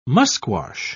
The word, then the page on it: musquash: British English